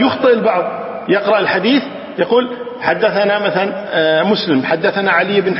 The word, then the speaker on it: حدثنا: لكن باللفظ لابد أن تقال حدثنا ما تقولها باللفظ ثنى هي تكتب ثنى لكن تلفظ حدثنا